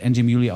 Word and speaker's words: Anjimile: Angie Muley, wer es nochmal nachgucken möchte und jetzt noch keinen Blick auf die Playlist werfen konnte, wird äh, hinten rausgeschrieben mit M-I-L-E, also A-N-J-I-M-I-L-E. Das Ganze wird Angie Muley ausgesprochen, was mit der Herkunft des Künstlers zu tun hat.